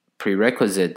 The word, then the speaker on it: prerequisite: 然后要注意这个 prerequisite 的重音呢，是在 re 那个 re 的发音。 prerequisite 跟前面 requirements 呢。的重音呢，只是在第二个音节，就是 qui 的部分，所以是 requirements 跟 prerequisite，所以练习的时候可以特别注意一下。